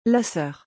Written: La soeur

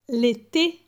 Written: L'ete

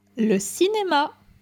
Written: Le cinema

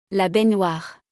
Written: La baignoire

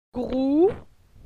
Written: Grou

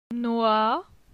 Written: Noi

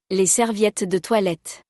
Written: Les serviettes de toilette

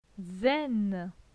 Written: Zen

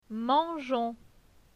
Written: Mangeons

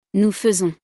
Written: nous faisons

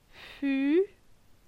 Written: Fu

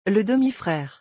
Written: Le demi-frere

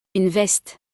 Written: Une veste

